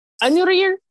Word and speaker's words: Unir: And he is the only son of so Liam's dad's name, and how I pronounce it, is Anurir. It's kind of how you have to like roll your R when you say it, but the way it's spelled is just like UNIR.